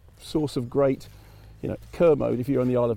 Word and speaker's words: Kermode: but it is Kermode, and the easiest way of remembering it is like Kermit. And you know, believe me, nobody gets it right, but that's it. And the actual proper pronunciation is Kermit.